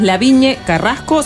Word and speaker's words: Lavigne Carrasco: También pueden chequear en el Instagram, que es Laviñe Carrasco, se escribe LAVIGNE Carrasco, y van a ver ahí toda la info que tienen.